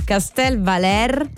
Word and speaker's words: Castel Valer: Castel Valer, con l'accento sulla E, e Danaunia.